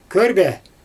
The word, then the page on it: körbe: Xyzyl